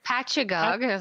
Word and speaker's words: Patchogue: Patchogue is how I've been told it said. – Patchogue, New York on November 14th.